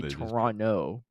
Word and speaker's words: Toronto: But Americans say Toronto because you see the T, right? Why would the T be silent? But apparently, if you're a true Canadian, a true Torontian, I don't even know, someone from Canada, from Toronto, you say Toronto without the T.